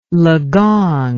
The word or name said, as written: legong